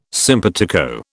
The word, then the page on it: simpatico: British English